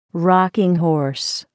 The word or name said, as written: rocking horse